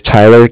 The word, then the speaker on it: Tyler: My name is Tyler, T-Y-L-E-R. – Yep. – Calling with Cardiff, it's spelled C-A-R-D-I-F-F, as in Frank.